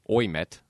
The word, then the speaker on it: Ouimet: It's spelled O-U-I-M-E-T, which is interesting to me. You know, Ouimet, but it's pronounced Ouimet. So we met the other day, yes.